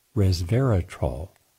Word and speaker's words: resveratrol: The compound, you've heard me mention many times, resveratrol, R-E-S-V-E-R-A-T-R-O-L, three syllables, which are found in grape skins and grape seeds.